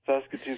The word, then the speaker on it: Saskatoon: Saskatoon? Saskatchewan? Sasquatch? What? Okay. I'll just go the other way. That's all I know. – Saskatoon, Saskatchewan.